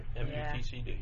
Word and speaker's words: MUTCD: MUTDC manual. Did mm-hmm. I say that right? – MUTCD. – Yeah, MUTCD.